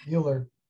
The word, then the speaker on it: Mueller: I don't know if I pronounced your name right. – Mueller. – Yes.